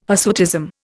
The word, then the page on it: hirsutism: Indian